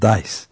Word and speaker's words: DICE: DICE kısaltılmışı, zarlar demek gibi okunuyor.